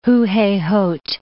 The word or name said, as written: Huhehot